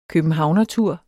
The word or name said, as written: københavnertur